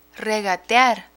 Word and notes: regatear: Spanish

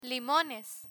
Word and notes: limones: spanish

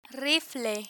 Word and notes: rifle: spanish

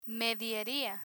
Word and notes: medieria: Spanish

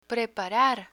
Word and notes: preparar: Spanish

